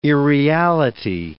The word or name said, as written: irreality